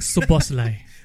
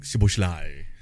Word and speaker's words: Szoboszlai: Liverpool one, I cannot pronounce lah. The Subuhudalai, whatever. – It's Dominic Szoboszlai. – Okay, Dominic Szoboszlai.